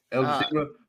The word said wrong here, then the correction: Idris Elba: playing the role of Blade. – Idris Elba. – Idris Elba, okay.